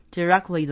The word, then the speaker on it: directly: directly 的那个 T 不太发音，就是 directly, 就是不用把 T 发出来，不会说 directly, 因为这样太多子音了，所以就是 directly at them。